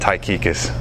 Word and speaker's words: Tychicus: That's you actually pronounce that Tychicus.